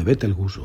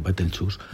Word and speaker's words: Betelgeuse: A principios de este mismo año, Todas las alarmas se dispararon cuando los astrónomos detectaron que el brillo de Betelgeuse o Betelchus, depende de quién lo pronuncie,